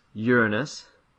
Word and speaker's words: Uranus: The last planet they knew was Uranus. – Yeah. – Is that how it's pronounced? – Yeah, I believe that's the perfectly accepted version of the pronunciation.